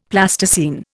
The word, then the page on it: Plasticine: Indian